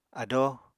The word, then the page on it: adóo: Apatani